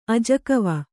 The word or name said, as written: ಅಜಕವ